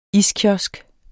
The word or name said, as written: iskiosk